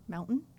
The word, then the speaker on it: mountain: Say the word mountain. – Mountain? – Say it with the way it's spelled with a T.